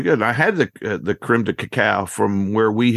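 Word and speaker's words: crème de cacao: Creme de cacao. – Creme de cacao.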